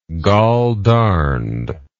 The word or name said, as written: goldarned